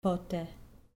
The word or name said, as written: pote